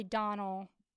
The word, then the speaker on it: Domhnall: You probably don't pronounce the M. It's probably Donald. – Donald Gleeson. That is most likely correct.